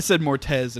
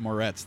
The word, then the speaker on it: Moretz: I said Mortez instead of Moretz the entire time, and I said it a lot.